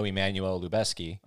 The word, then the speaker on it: Emmanuel Lubezki: But I did know, I know Emmanuel Lubezki.